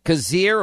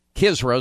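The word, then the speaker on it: Khizr: That's not as big a deal as Kazir, or Kizra is really the proper pronunciation of Khan.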